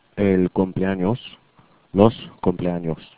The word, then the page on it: cumpleaños: spanish